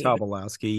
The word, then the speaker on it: Tobolowsky: Tobolowsky, yeah.